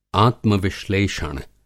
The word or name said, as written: आत्मविश्लेषण